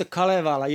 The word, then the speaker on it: Kalevala: The Kalevala, is that how you pronounce it in Finland? – Yes, that's the Kalevala. Yes.